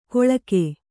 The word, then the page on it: ಕೊಳ್ಕೆ: koḷke